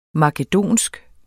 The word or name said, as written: makedonsk